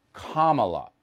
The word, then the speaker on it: Kamala: That's how you say her name. Kamala, like comma.